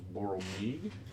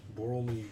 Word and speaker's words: Borlmeeg: The one from the swamp was Boral Meeg. – Boral Meeg. – Can you spell it? – B-O-R-L-M-E-E-G.